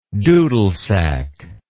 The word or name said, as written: dudelsack